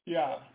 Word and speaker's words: Yah: But in the English, it's spelled with a J? – Right. – Okay, and it's just Yah. That first one is telling you, the first part is telling you how it's spelled. The second part is telling you how it's pronounced. So you see Y-A-H-H? – Right. – Yah. And then comma, Y-A-W? – Right. – Okay, that's just how it's pronounced. Yah.